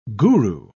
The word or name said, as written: guru